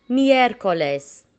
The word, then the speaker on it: miércoles: Miércoles. So four syllables.